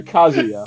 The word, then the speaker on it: Kazuya: Yeah, Kazuya.